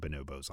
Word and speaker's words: Bonobos: Because I wanted to not say it like the monkey, but it is like the monkey. – It Bonobos.